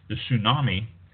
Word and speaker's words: tsunami: A nuclear power plant in Japan, following the huge earthquake and tsunami, the T is silent, it also released a radioactive cloud that bathed North America in cesium-137.